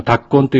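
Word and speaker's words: 立根: 私たちがボランティア活動で宿泊する場所は、タッコン地区公民館というところですけれども、このタッコンというのは、立つ、座るの立つに根っこの根というふうに書いてタッコンと読みます。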